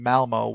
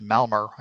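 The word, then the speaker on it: Malmö: Yeah, I actually have been dipping into this album inadvertently because the last couple of times I was in Malmo, which Joyce insists is pronounced Malmer.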